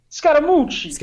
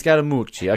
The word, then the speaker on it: Scaramucci: Because Scaramucci. – Scaramucci.